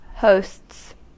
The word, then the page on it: hosts: American English